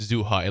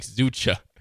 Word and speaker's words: Zoucha: Okay, well, it is pronounced Zuha. It looks like Zucha. His last name is spelled Z-O-U-C-H-A, which looks like Zucha, but yeah, it's Zuha.